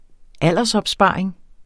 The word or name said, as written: aldersopsparing